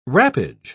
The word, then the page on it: wrappage: British English